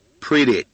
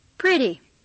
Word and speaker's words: pretty: Pretty. – Pretty. – Step three. 我们一起来大声喊吧。Let's yell together. 1, 2, 3. – Pretty. – Pretty. – Pretty. – Pretty. 我特别要强调一下，要读 pretty，千万不能读成 pretty，那是英国人的读法。美国人把 t 变成了 d. Pretty.